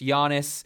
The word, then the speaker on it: Giannis: Giannis, that's how you pronounce his name if you haven't heard it.